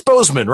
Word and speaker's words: Bozeman: It's Bozeman, right? – Yeah, you did.